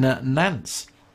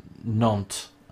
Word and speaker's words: Nantes: Recently, the first components for the freighter were produced at the Airbus Atlantic plant in Nantes. Airbus continues. I've said it wrong. Apparently, say that again. Nantes.